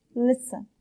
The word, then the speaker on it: Listen: Read and listen. Number 1. Read means خواندن. Number 2. Listen. بچه ها استرس روی حرف ال هست. Means گوش دادن. تی داخل این کلمه تلفظ نمیشه.